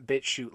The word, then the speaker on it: BitChute: BitChute is spelled BITCHUTE. It's not BitChute like shooting something. It's BitChute like a coal chute or something like that.